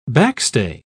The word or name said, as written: backstay